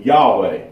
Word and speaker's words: Yahweh: And so you get this pronunciation of Yahweh right here. Now, of course, most of the time in English usage, we use Jehovah. Now, we know there's no J's in Hebrew.